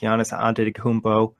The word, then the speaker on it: Giannis Antetokounmpo: Obviously, Kristaps Porzingis going against Giannis Antetokounmpo, the unicorn versus the Greek freak. – That was a nice pronunciation there.